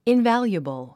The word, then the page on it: invaluable: invǽljuəbl; インバリュアブル